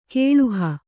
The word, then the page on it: ಗಳುಹು: gaḷuhu